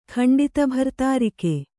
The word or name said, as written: ಖಂಡಿತಭರ್ತಾರಿಕೆ